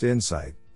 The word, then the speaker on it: INSCIT: In this work, we present INSCIT, pronounced insight, a dataset for information seeking conversations with mixed initiative interactions.